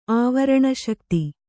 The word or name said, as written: ಆವರಣಶಕ್ತಿ